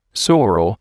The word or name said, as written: sorrel